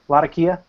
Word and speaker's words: Latakia: Cavendish, Latakia. Is that how you say it? Latakia? – Yeah. To my knowledge, that's how it's said.